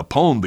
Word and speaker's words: pwned: It's spelled P-W-N-E-D, and it's pronounced pwned.